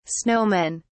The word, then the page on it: snowmen: British English